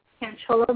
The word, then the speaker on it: Canchola: Canchola.